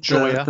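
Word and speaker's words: Gioia: I was watching an interview with Dana Goya. Is that how you say his name? – Joya. – The poet? Joya, yeah.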